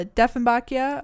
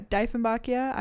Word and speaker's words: Dieffenbachia: It's called a Diefenbachia or a Diefenbachia. I say both, but I really don't care how people pronounce it.